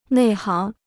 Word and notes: 内行: nèi háng

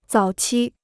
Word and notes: 早期: zǎo qī

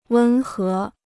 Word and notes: 温和: wēn hé

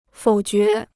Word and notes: 否决: fǒu jué

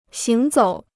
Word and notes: 行走: xíng zǒu

